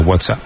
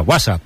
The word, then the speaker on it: WhatsApp: No, peor aún, con acento en la primera. WhatsApp, ¿no? Un WhatsApp.